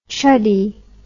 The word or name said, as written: chuddy